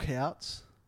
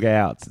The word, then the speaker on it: Houts: Brooke Hewitts. – Why are you saying it like that? Brooke Outs. It's a silent H.